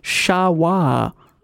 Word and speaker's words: Sherpa: Sherpa originally meant people from the east and is actually pronounced Sha-wa. by the Sherpa people themselves, which I did not know.